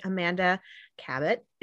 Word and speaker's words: Amanda Cabot: And I have with me today, Amanda Cabot. Am I pronouncing your last name correctly? – Yes, you are. – I am. Okay. Amanda Cabot, who publishes delightful historical romance, which I have always long been a fan.